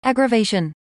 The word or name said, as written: aggravation